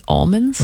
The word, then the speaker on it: almonds: How do you say almonds? – With almonds. – He goes, almonds. – Almonds. – You just like leave out the L? – Almonds. It's like my accent.